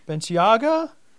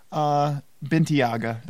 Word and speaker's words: Bentayga: And then also this week, the embargo got lifted on the Bentayga. – This is the segment we learn how to pronounce a car name. – Well, I mean, look at that. – Yeah. Bentayga.